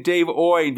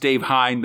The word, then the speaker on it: Hine: I recap this whole story saying Dave Oyn because that's what the Nerve Center told me how to pronounce it, and I want to pronounce people's names right. But now that as we're re-recording this, some episodes have played and people have told me that that was in fact a joke and that his name is pronounced Dave Hine the way it's spelled.